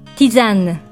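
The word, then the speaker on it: tisane: フランス語ではどういうかご存知ですか実はこのように書いて、ティザンヌ、ティザンヌと発音します。真ん中の S は母音に挟まれているので、 Z の音になり、ティザンヌとなります。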